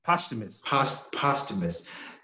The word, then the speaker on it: posthumous: They said that, is it pronounced posthumous? I've never knew how to say this word. – Posthumous.